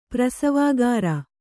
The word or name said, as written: ಪ್ರಸವಾಗಾರ